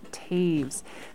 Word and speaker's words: Toews: That is spelled T-O-E-W-S, like toes, pronounced Taves.